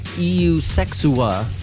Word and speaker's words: Eusexua: From their latest album released last year, EU Sexua. That's how I pronounce it anyway. It's spelled E-U-S-E-X-U-A, EU Sexua.